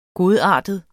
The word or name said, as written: GODARTEDE